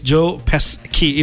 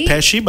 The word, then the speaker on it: Pesci: So it's Joe Pesci. It's Pesci. – Pesci, but there's a C there. S-C-I. – Written Pesci. – Yeah. You know, it's written Pesci, but pronounced Pesci.